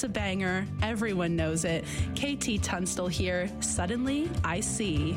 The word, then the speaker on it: KT Tunstall: KT Tunstall.